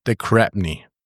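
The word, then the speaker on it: de Crespigny: Nick Champion der Cratney. Geschrieben wird er Kresbigny, aber Nick Champion de Crabney. So wird er ausgesprochen.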